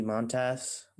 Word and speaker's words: Montas: So maybe they pursue a starter, you know, like Frankie Montas.